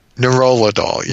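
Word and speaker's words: nerolidol: Is it Neurolidol? – Neurolidol, yes.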